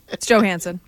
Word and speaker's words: Johansson: It's Johansson. – Mm-hmm. Well, it's the soft J.